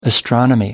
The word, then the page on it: astronomy: American English